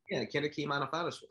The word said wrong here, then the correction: Anifowoshe: I can't pronounce it. Anifowoshe. – Yes. Anifowoshe.